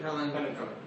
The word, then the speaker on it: palindrome: Yes, in French it's called palindrome. Something. How do you say it? – Palindrome. – Palindrome.